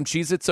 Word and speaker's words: Cheez-It: Like, if you were to make it plural, if you were to say, hey, you want some Cheez-Its over here, that would technically be wrong. They want you to pronounce it just Cheez-It. Like, that's the name. You never put an S on there.